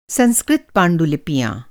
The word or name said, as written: पांडुलिपि